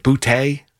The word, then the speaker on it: Boutte: The pass to Booty. I think that's how you pronounce his name. Is it Boutte? You know, Boutte. Thank you.